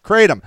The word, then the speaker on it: Kratom: Okay, yeah, okay, so Emery says it comes in shots, pills, and powders, but it's called, pronounced Kratom.